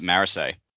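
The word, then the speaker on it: Marasi: So all I have is the pronunciations that are in my brain, and that is why I say Marisai when I believe the correct pronunciation is Marassi or something like that. And you've listened to some of the audiobook, maybe you know. – No, the audiobook pronounces it Marisai.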